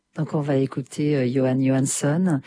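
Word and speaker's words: Jóhann Jóhannsson: Ouais, c'est Johan Johansson.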